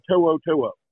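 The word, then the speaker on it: To'oTo'o: Because when you look at somebody like Henry Toho Toho, and that's how you say his name.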